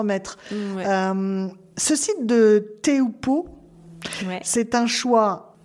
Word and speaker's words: Teahupo'o: alors c'est le spot de Teupo. Teupo, voilà, qui s'écrit T-E-A-H-U p 2 Voilà, ah.